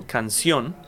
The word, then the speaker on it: canción: Canción, the stress is in the last syllable, canción, mm-hmm.